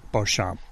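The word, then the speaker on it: Béchamp: Well, as you mentioned back, Louis Pasteur, and the other man's name is Anton Beauchamp. is how that's pronounced.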